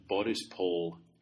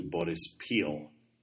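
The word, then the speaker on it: Boryspil: Last week I mentioned Borispol Airport as opposed to Borispil. For those who aren't clued into the politics of Ukrainian spelling and pronunciation, O changes to I often in Ukrainian names. So, for example, Kharkov is the Russian pronunciation. Kharkiv is the Ukrainian pronunciation.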